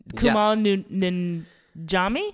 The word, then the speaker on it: Kumail Nanjiani: Kumail Nanjiani? – Yes, that sounds right to me.